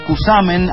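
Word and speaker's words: Cushamen: Gracias a la persona que a través de WhatsApp nos corrige que el Los Cusamen, así se pronuncia, con la S y no con la Y,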